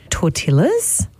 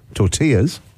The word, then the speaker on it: tortillas: Tortillas. – Tortillas. – Tortillas, sorry. Tortillas. Oh, that's so funny. I've never said that right.